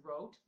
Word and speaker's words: Roth: The German pronounce it Rote, but oh, Dominic, he will know that I stuffed that up as well.